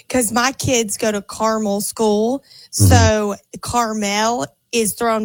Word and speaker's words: Carmel: It's not caramel. It's Carmel.